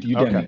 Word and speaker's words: Udemy: How do you spell Udemy? – U, like the letter U, and D E M Y. – M-Y. Udemy.